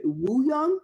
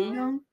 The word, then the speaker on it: Wooyoung: And him and, was it Wooyoung? Is that how you pronounce his name? Mm-hmm. Wooyoung? – Oh, yeah.